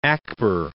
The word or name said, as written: Akbar